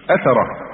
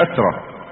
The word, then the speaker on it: أثرة: فمن ثم قال عليه الصلاة والسلام: الأنصار شعار والناس دثار، إنكم ستلقون بعدي أثرة أو أثرة أو إثرة، تنطق بالثلاث،